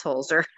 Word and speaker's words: Holzer: Is it Holder or Holzer? It's Holzer.